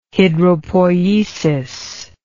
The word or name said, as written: hidropoiesis